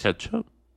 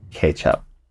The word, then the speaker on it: kecap: It's spelt K, no, yeah, KECAP, but I think that's where the word ketchup from comes from. So I think you pronounce it ketchup.